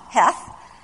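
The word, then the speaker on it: heth (ח): Because the Hebrew letter is the letter chaff, or heth, it's pronounced cha, heth, and it's a picture of an enclosure, a wall, a fence, or a boundary.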